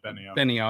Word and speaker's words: Benio: Is it Benio? – Yeah, Benio. – I just want to make sure I pronounce that right.